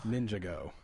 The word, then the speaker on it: Ninjago: And Lega Ninjago? – Ninjago. – Sure. – I think that's how you pronounce it.